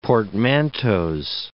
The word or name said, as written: portmanteaux